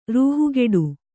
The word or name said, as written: ರೂಹುಗೆಡು